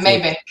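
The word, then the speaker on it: Mabank: is it Maybank? Am I saying that right? – Yeah, Maybank.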